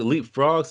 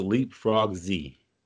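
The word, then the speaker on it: LeapFrogz: No, it's leapfrogs. Pretty much, all of their baits, you know, the Z is just, you know, it's you don't pronounce it by itself. It's just part of the word, yeah.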